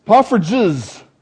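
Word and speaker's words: poffertjes: Poffridges, not G's.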